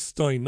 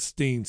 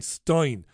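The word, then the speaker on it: Steyn: Anyway, it has ruled that GB News and a programme that was presented by Mark Stein, I think it's pronounced Stein, not Steen, Stein, did break Ofcom rules.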